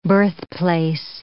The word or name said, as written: birthplace